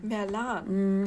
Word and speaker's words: Merlan: Dann kam Kate, Kate Merlan oder Merlin, wie spricht man das? Merlan. Merlan. Mm, hat sie zumindest im ähm, Einspieler gesagt.